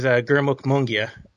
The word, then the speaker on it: Gurmukh Mongia: My name is Gurmukh Mongia.